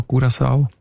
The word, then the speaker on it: Curaçao: Was sagt denn die Datenbank zu den Menschen von Curaçao? – Curaçao heisst die Insel, habe ich gelernt. – Sorry? – Mit Ü, genau.